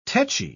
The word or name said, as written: techy